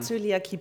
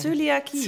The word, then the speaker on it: Zöliakie: Ich habe Zöliakie, bitte gewöhn dir das an. – Das ja, ich kann es nicht, kann ich aussprechen. Zöliakie. – Zöliakie.